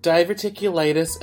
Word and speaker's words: diverticulitis: It's pronounced diverticulitis and a barium enema.